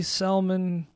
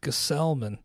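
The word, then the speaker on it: Gsellman: Finally got some clarification on his pronunciation. I'd heard it silent G, Selman, Gelman. I guess it is just as it's spelled out, Gesellman.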